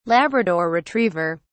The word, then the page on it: labrador retriever: British English